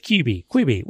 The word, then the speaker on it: Quibi: Quibi. What did we decide we were going with? – I think Quibi, because it has a U in it, so I feel like you're meant to pronounce it.